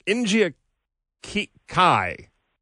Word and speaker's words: Nija Kai: And we have Injia Kai.